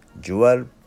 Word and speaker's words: Jewel: では明日の What the hint のアンサーズワードは Jewel Beetle、ジュエルビートル。この発音また難しいんですよね。 J-E-W-E-L、ジュエル、まあ宝石の虫で、ジュエルビートルになりますけども、これはですね、タマムシのことです。